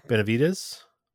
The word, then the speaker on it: Benavides: But anyways, the overall winner was Kevin Benavides. – I think it's how you pronounce and, it. – Yeah. Yep, it is how you pronounce it.